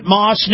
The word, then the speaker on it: Maus: Yeah, buddy, and let me tell you about my friends at Moss Nissan in Newport Richey and Crystal River. Moss is spelled M-A-U-S, Moss Nissan.